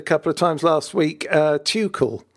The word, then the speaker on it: Tuchel: and it is Thomas Tuchel. Mm. You're absolutely right. It's slightly on the CH.